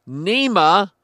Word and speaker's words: NEMA: NEMA rhymes with SEMA. No, it rhymes with FEMA, who are accused of culpability over the tragedy.